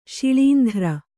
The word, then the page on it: ಶಿಳೀಂಧ್ರ: śiḷīndhra